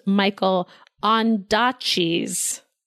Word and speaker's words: Michael Ondaatje: I said I was mispronouncing his name last week or 2 weeks ago, whenever this was, but I completely mispronounced Michael Ondaatje's name.